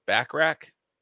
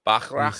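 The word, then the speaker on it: Ebon Moss-Bachrach: Is that how you say it? Backrack? Backrack. That doesn't sound right. Bachrach. Bachrach.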